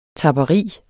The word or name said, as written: tapperi